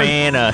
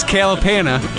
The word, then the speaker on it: Kalapana: This is Calipana. the hurt. – That's the most Michigan pronunciation of a Hawaiian word I've ever heard. – Kalapana. – Kalapana. – Kalapana. This is Kalapana. – Kalapana. – Sorry, Kalapana.